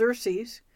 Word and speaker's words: Xerces: Our name, which is pronounced Xerces,